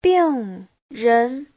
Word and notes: 病人: bing4ren2

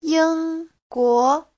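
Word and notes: yingguo: ying1guo2

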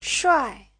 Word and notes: shuai: shuai4

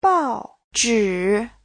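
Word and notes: 报纸: bao4zhi3

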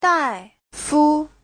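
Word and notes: 大夫: dai4fu5

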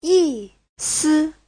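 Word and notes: yisi: yi4si5

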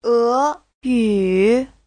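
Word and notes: eyu: e2yu3

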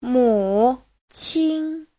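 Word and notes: 母亲: mu3qin1

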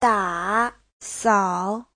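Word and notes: dasao: da3sao3

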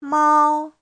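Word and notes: 猫: mao1

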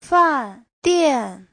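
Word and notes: fandian: fan4dian4

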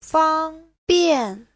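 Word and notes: fangbian: fang1bian4

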